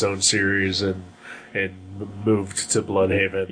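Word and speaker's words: Blüdhaven: But yeah, the B-L-U-D with the umlauts is over the Haven. – I did not see the umlauts. – Yeah, alright. – It was there. – They pronounce it Bloodhaven. – Yes, they do. – In the show, because they are not German. They are Americans.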